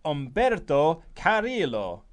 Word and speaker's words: Humberto Carrillo: H is always silent unless it is with C or S or T. So it's for Humberto Carrillo is pronounced Humberto Carrillo or Carrillo.